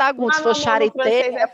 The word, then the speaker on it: Charité: E o outro na Charité. Me diga logo como é que fala. charité. Charité. – Ensina. Não, não é possível. Charité. É charité. Charité. Ah, parece francês, Mas né?